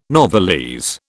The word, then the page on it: novelese: British English